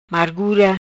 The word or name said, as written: Margura